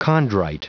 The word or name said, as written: chondrite